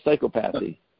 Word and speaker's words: psychopathy: I call it psychopathy. does the profession call psychopathy psychopathy? – It's a psychopathy, yes. Psychopathy, yes. – That's how it's said. – It's not syncope. It's not syncope. That's another word. – Yeah. It's not psychopathy. It's psychopathy. Mm-hmm. All right. – Psychopathy. That's right.